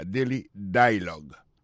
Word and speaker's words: Daily Dialogue: Comme n'a pas mis des accents, sous bel accent en anglais, sous belle prononciation en anglais, le mot dialogue a gagné deux syllabes là-dedans. Daily Dialogue, alors, il y a pesé plus sous premier syllabe là. Daily Dialogue.